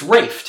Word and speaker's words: Ralph: How do you say his name? It's Rafe, technically, right? Rafe Fiennes? Yeah. He comes in, right. He comes in. It's spelled Ralph, but he says it's Rafe.